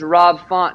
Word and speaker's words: Rob Font: I did call out Fab Rant. His name's Rob Font, and people were like, you said his name wrong.